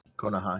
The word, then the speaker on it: Konoha: One thing I am thinking, though, is with the world of Naruto, how do you say it? Konoha? – Konoha, yeah.